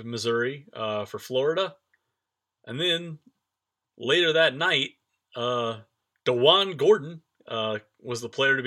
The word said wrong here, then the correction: Deshaun: Now, you're already pronouncing it wrong. – What is Deshaun. It? Deshaun. I'm sorry, Deshaun. Deshaun Gordon came through in another Purdue transaction.